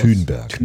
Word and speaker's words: Thunberg: Übrigens, so spricht man sie richtig aus. Thunberg. – Ne? Thunberg.